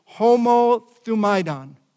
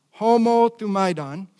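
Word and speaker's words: homothumaidon (ὁμοθυμαδόν): With one accord, three words in English is one word in the Greek, and I'm going to pronounce it like this, homothumaidon, homothumaidon. It's important. Let me tell you why. The first part of the word, homo, H-O-M-O-U, it means together, in unison, or in agreement.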